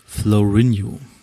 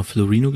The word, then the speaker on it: Florinho: Ähm, Spot-on, heute haben wir ein bisschen, also einmal, zuerst möchte ich euch den guten, jetzt muss ich den Namen richtig aussprechen, Florinio ich habe, glaube ich, letzte Folge mal Florino gesagt, vorletzte Folge.